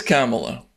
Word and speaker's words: Kamala: I think it's Kamala. – All right, Kamala.